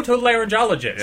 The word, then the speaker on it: otolaryngologist: An otolaryngologist? – That's not actually the way you pronounce that word. – But it's are you somebody who studies otolaryngology? – No. Oh, otolaryngologist. – Yeah. – Very nice. Because her name starts with E-A-R.